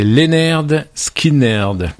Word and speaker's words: Lynyrd Skynyrd: C'est donc, alors, je vous le prononce de la bonne manière, hein. C'est Lynyrd Skynyrd.